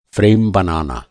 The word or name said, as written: फ़्रेम